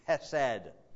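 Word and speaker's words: chesed (חסד): That's the word, this is the word that you have to spray when you say. It's the Hebrew word chesed. And if you're not saying it correctly, you're not spraying.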